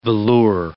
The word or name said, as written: velour